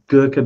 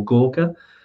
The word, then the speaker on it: Gorkha: You've got Gurkha beer, which they call Gorkha, is how it's pronounced in Nepal.